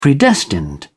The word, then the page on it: predestined: British English